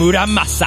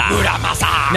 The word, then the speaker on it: Muramasa: They are called Masamune. – Masamune. – And Muramasa. – Muramasa. – Now, for Amadeus, man. – For God's sake, man. – Those are the only accepted pronunciations.